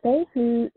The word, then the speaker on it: Fehu: Can you tell us a little about the June 29th to July 14th cycle, which is, I want to make sure I pronounce this right, Fehu, manifesting the sacred self? – That's right. Yeah, Fehu is the rune of wealth.